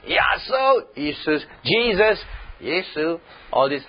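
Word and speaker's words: Yesu (耶稣): J-E-S-U-S. And Jesus, Jesus. Or, in Mandarin, is Yesu.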